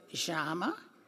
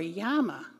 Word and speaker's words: llama: I said, yes, but Santa Claus likes you to pronounce it Jama or Yama, because I was in Peru. But when they come from Peru, and that's how it's pronounced.